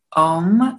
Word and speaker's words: Ohmme: Again, I'm not sure that they're underrated, but there's a band based out of Chicago called Ohm. It's spelled O-H-M-M-E.